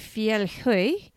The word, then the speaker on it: Fjellhøy: I can tell you that is exactly how you pronounce it because I asked my boyfriend who's Swedish and he's like, because I was like, Fjellhøy? He's like, no, it's an O with a slash through it. It's Høy.